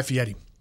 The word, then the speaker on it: Fieri: Guy Ferrer, I never can say his name. Guy, you know, the Diners, Drive-Ins, and Dives guy. – One of my favorite. – That has cool hair. – Yeah, Guy Fieri.